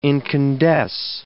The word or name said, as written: incandesce